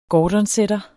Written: gordonsetter

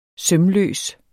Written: sømløs

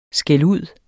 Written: skældud

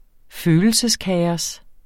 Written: følelseskaos

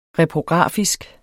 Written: reprografisk

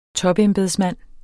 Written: topembedsmand

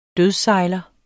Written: dødssejler

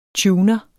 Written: tuner

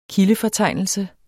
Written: kildefortegnelse

